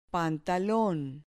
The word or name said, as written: pantalon